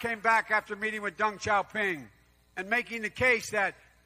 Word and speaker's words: Deng Xiaoping: So we have Joe Biden claims that he worked with Chinese leader Deng Xiaoping. I don't know if I said that right, who cares anyway. – Deng Xiaoping.